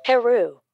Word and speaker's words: Heru: Heru. – Heru to you too, Oracle? – No. Horus's name is Heru in the Egyptian pronunciation.